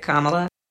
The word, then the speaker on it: Kamala: I usually help people pronounce it by saying, well, just think of the hyphen, a comma, and then add a la at the end, and that's it, Kamala.